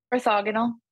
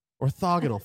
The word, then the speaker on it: Orthogonal: Orthogonal. – Orthogonal.